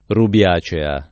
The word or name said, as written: rubiacea